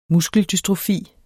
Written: MUSKELDYSTROFI

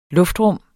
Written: luftrum

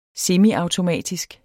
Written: semiautomatisk

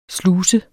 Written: sluse

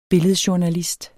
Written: billedjournalist

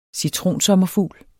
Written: CITRONSOMMERFUGLE

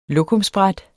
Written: lokumsbræt

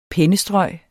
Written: pennestrøg

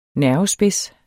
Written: nervespids

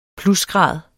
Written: plusgrad

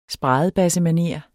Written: spradebassemanér

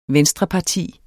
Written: venstreparti

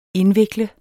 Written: INDVIKL

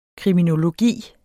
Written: kriminologi

